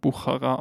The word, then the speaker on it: Buchara: aber ja, man spricht es halt Buchara aus.